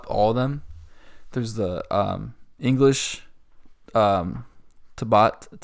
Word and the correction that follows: Tibetan: Um, Tibetan.